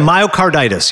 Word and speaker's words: myocarditis: Yeah, myocarditis, yep.